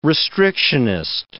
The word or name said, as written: restrictionist